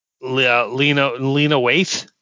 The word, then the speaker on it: Lena Waithe: Lena Waithe. Am I saying that right? – Yep.